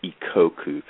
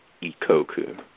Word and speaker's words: Ikoku: Sure, it's Ike Ikoku. Kind of rhymes with he owes you, Ikoku.